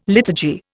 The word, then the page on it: liturgy: Indian